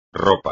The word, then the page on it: ropa: espagnol